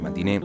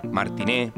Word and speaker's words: Matiné: El nombre es Tarde para Matiné, es un poco largo y a veces los conductores se traban al pronunciarlo. Le sale Martiné, o Mantiné, o es temprano para la disco, qué bobos. Por eso, en ocasiones los escuchará usar ETPM, que son las iniciales.